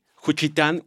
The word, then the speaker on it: Juchitán: Juchitán, Oaxaca.